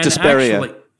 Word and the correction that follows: diaspora: Diaspora is the word.